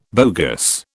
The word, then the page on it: bogus: British English